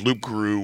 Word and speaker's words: loup-garou: And they have what they call Lougarou, which is a mispronunciation of the French loup-garou, which fucking werewolf.